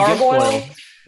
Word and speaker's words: Guilfoyle: I will say that my favorite part was, and I'm going to butcher her last name, Guilfoyle? – Oh, Kimberly Guilfoyle.